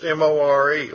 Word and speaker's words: myrrh: And myrrh there is 7453 in the Strong's, and it's spelled M-O-R, but it's pronounced M-O-R-E, like our more.